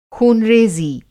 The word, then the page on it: ख़ूनरेज़ी: خوں ریزی